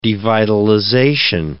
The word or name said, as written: devitalization